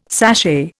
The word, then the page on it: sashay: Indian